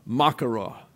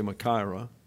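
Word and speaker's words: machaira: There's the Roman makara, is the way you pronounce it in the Greek, but we say makaira.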